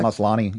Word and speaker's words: Maslany: We have Tatiana Maslany. Is that how you pronounce her name? – I pronounce it Maslany.